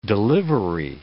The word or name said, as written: delivery